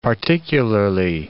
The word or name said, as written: particularly